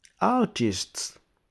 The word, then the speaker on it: artist: Artist. Artist. Il y a les R qu'on ne prononce pas. R. On dit c'est R, mais c'est A.